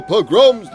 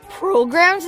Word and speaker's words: pogrom: And many of the Jews came to escape the terrible pogroms that were taking place in Europe at that time. – Programs, Zadie? What are programs? – No, the white is not program, it's pogrom. A pogrom was when, how do I say it? – It's okay, Heshi, I'll explain.